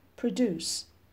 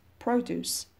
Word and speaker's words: produce: Part F Stress Record where the stress falls on a multi-syllable word, especially if the stress changes between word classes. For example, produce, verb, produce, noun productive adjective به این صورت استادی یونیت دو تمام میشه میرسیم قسمت تمریناتش صفحه 13 2.1 Organize the words into the topics below.